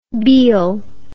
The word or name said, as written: beale